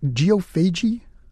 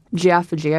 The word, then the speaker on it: geophagy: Professor, have I pronounced that correctly? Geophagy? That's right. You could also say geophagy. I've heard it. Tomato, tomato kind of thing.